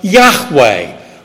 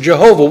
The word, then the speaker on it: Yahweh: In Hebrew, it's pronounced Yahweh. Or in English, we might pronounce it Jehovah.